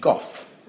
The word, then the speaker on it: golf: Now, golf for us always has an L. But in those days, there was a prestigious pronunciation, goff, without the L.